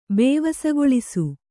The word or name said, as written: ಬೇವಸಗೊಳಿಸು